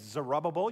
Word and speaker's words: Zerubbabel: I know that's hard to pronounce, but his name's Zerubbabel, you'll get that, and his business is about rebuilding the temple.